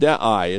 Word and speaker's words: dei: And in fact, there's a Greek word, I'll pronounce it dei, it's spelled D-E-I in transliteration.